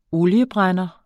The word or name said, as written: oliebrænder